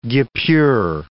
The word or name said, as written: guipure